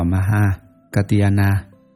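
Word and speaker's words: Mahākātyāyana: Ngài Maha Ca Trên Diên, Ấn Độ đọc là Maha Katiana, chữ Hán là Đại Văn Sức, là dòng Bà La Môn, là bậc có tài nghị luận giỏi đệ nhất.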